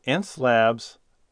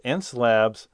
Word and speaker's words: Xencelabs: And it's called Ence Labs Pen Tablet Review, Already Better Than Wacom. And it's spelled X-E-N-C-E-L-A-B-S.